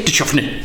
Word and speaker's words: Duchovny: Du, Dusch, Dusch, Dusch, Dush Dusch, Haufny Dusch Haufny David Dusch Haufny D U C H O V N Y Hvordan vil du udtale det? Duchovny. David Duchovny. Det er Duchovny.